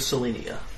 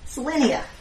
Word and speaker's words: Sallenia: It is the city of Selenia. – Can you spell that for me? – It is S-A-L-L-E-N-I-A, Selenia. – Selenia, cool.